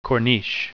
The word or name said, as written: corniche